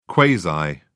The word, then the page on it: quasi: British English